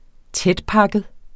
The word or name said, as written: tætpakket